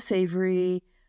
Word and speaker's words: Savory: Is his name, do you pronounce it Savory? S-A-V-O-R-Y. – Oh, Alan Savory. – Savory, right. Yes.